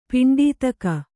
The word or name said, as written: ಪಿಂಡೀತಕ